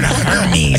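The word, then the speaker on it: Hermès: What about, let's go to another luxury brand, H-E-R-M-E-S. Hermes? – Hermes. – Hermes? – Hermes? – Hermes. We're not Hermes. – I can't afford them. – It's, Hermes. A-I-R. – Hermes. – That's how you should pronounce it.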